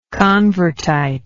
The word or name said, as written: convertite